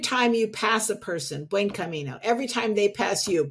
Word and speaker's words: Buen Camino: Buen Camino.